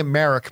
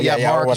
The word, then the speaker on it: Marrok: M-A-R-R-O-K. – Yeah, I was pronouncing it Merrick, but Marok is probably right.